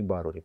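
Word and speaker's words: Ибаррури: Мало кто знает, что Долорес и Барузи чаще, кстати, правильнее будет именно и Барури, поскольку в испанском языке ударение идет на второй слог, вот.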